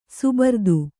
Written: ಸಭರಿತ

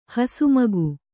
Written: ಹಸುಮಗ

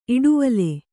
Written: ಇಡುವಲೆ

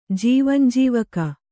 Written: ಜೀವಂಜೀವಕ

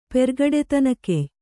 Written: ಪೆರ್ಗಡೆತನಕೆಯ್

